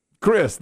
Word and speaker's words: Chris: Well, we've had the things back for a little while now, and somebody pointed out my name, Chris, the Chris part, C-H-R-I-S, is spelled C-H-R-I-S-T.